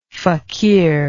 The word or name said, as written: faqir